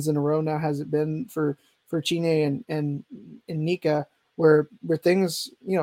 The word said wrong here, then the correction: Chiney: Sorry, did I say that wrong? – Chiney and Nick.